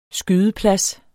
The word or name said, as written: skydeplads